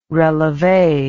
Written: releve

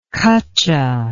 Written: kacha

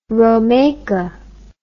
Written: romaika

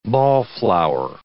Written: ball-flower

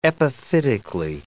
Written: epiphytically

